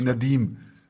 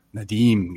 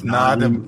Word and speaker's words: Nadim: Das ist wie Nadim. Nadim, genau. – Genau, nicht Nadim, was dir ja auch hm.